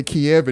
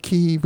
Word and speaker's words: Kyiv: We can't say Kiev anymore because that's right. the Russian pronunciation. I see the mayor of Kyiv, who's Vitaly Klitschko, former heavyweight boxing champion, about 6'7".